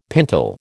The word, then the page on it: pintle: American English